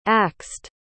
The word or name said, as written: axed